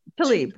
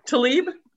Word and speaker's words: Tlaib: I'm going to beat this Tlaib. – Up. – Tlaib. – What? Tlaib? – Uh-huh.